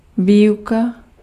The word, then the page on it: enseignement: France; [ɑ̃.sɛ.ɲə.mɑ̃]